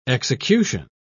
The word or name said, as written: execution